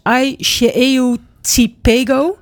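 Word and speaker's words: Ai Se Eu Te Pego: Als het goed is, spreek je het uit als Ai Cheio Tipego.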